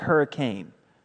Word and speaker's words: Hurricane: It's spelled just like hurricane. But if you live there, you say Hurricane.